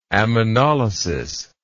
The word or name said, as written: ammonolitic